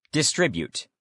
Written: distribute

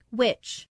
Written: which

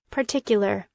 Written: particular

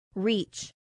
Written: reach

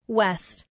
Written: west

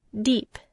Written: deep

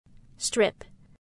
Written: strip

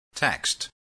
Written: text

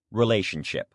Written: relationship

